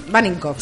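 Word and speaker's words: Wanninkhof: HBO, 26 de octubre tenemos una miniserie que es Dolores, la verdad, sobre el caso Vaninckoff, no sé si lo he pronunciado bien. – Vaninckoff, sí.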